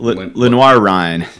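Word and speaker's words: Lenoir-Rhyne: But, you know, having just beaten Lenoir, is that how you say it? – Lenoir Ryan.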